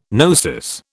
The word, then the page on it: gnosis: British English